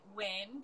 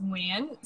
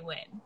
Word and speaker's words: Nguyen: So I pronounce it Win because it's phonetically close to how it's pronounced in Vietnamese, which is pronounced Nguyen. So easier without the accent, I just say Win.